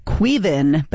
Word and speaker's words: Caoimhin: That was Doug, all caps, D-U-G. Song was called Jubilee. We had, it's pronounced Queevin, but it's spelled C-A-O-I-M-H-I-N.